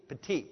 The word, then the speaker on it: Philippe Petit: Petit. I think this T is silent. He's French.